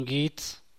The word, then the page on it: benduy: San Mateo Macuilxochitl Zapotec